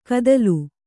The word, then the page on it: ಘೊತ್ತಲ: ghottala